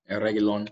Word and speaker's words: Reguilon: I don't know how to say his last name. Is it Reguilon? – Reguilon.